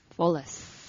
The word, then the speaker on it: Folios: Because I'm totally, you know, it's called F-O-L-I-O-S. How do you pronounce that? – Folius.